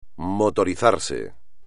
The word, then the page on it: motorizarse: espagnol